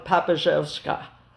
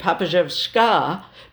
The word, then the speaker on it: Papizewska: It means daughter of Papizewska, Papizewski. So daughter of my dad. That's in the Russian form of the nomenclature. I started using Papizewska because that's when people started actually pronouncing my name properly.